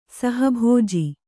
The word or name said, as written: ಸಹಭೋಜಿ